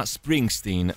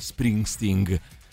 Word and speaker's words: Springsteen: C'è qualcuno che ce l'ha con voi, ce l'ha con te Maurizio, dite ai simpatici ragazzi del The Rock Show che Bruce si chiama Springsteen e non Springsteen.